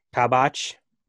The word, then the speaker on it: Kabak: The new center back, Kabak, is that how you say his name? – Kabak.